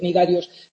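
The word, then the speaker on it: cnidarios: Veíamos que las primeras neuronas aparecían, acordaros, en los dos clados, las dos ramas de ese árbol evolutivo que contienen, cuyo nombre tiene una C que no se pronuncia, ¿vale? Esa es la clave para acordarnos. Los tenóforos y los nidarios, que acordaos, antiguamente estaban unidos en un grupo que se llamaba, que conocíamos como celenterios.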